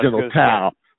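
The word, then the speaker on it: General Tso: The General Toe's Wings, as we call them at the store, which is pronounced General Tao.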